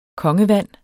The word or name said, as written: kongevand